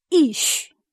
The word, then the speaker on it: Ish: 我再讲一个字，男人，男人希伯来文叫 Ish， Ish 这个 Ish 这个 E 它也是这一个 Aleph 字母， Aleph 这个字母不发音，它带着 E 的母音，所以它发 E，所以变成 Ish，男人或者是丈夫叫做 Ish，而男人丈夫也都是有上帝的形象。